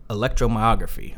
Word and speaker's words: electromyography: Electromyo electromyography or EMG is what more people have heard it more commonly called.